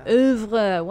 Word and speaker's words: oeuvre: inspired by the poetic realism of Arnold's oeuvre. With Big Little Lies, oeuvre, whatever. – No, you said it right.